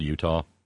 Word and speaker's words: Eutaw: You know, there's a place in Alabama called Utah. It's spelled E-U-T-A-W. Utah. You pronounce the W real good.